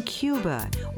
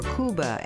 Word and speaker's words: Cuba: I'm Rosalie Howarth, and perhaps no country is more defined by its music than Cuba, or Cuba, as it's pronounced by native Spanish speakers.